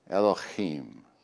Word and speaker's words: Elohim: And if you say it right, you got to kind of have a Elohim.